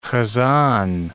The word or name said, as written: hazan